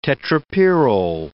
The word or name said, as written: tetrapyrrole